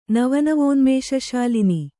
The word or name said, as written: ನವನವೋನ್ಮೇಷಶಾಲಿನಿ